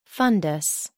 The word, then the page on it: fundus: American English